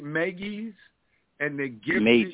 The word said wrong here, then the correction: Mages: Mages. – Mages. – Like a mage. So mages.